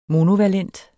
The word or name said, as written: monovalent